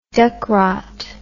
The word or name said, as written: Ducrot